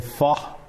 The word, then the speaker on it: pho: I've learned to say pho instead of, right? Right? Pho.